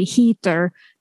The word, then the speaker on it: Heather: My name in Sweden is generally Heather instead of Heather, but I just go with it.